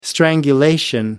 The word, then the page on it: strangulation: American English